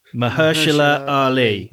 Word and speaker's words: Mahershala Ali: Mahershala Ali. – Right. I've been listening to that to try and... – And Viggy. – Yeah. – He plays... Mahershala Ali plays Don Shirley.